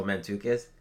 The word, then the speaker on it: Mantzoukas: I don't know how to pronounce this guy's last name, but Jason Mantzoukas. – Oh, Mantzoukas.